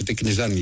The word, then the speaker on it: Τικνιζιάν: Ο Τικνιζιάν με τον Τικνιζάν, γιατί δεν προφέρεται, λέει, είναι μένει με το αλφα, Τικνιζάν λοιπόν να το λέμε.